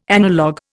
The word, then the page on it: analogue: Indian